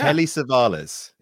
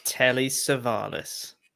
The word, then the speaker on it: Telly Savalas: Telly Savalas is how you pronounce it. – Telly Savalas.